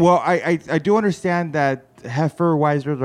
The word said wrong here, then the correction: Hefeweizen: So let me help you with that. – Yeah. – Hefeweizens. There is no N. There is no R.